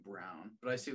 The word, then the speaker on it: Braun: Garrett, this guy's last name is Brown. It's spelled B-R-A-U-N. Think about that for a second. That's Braun. That's Braun. – Yeah, I was. – But it's Brown.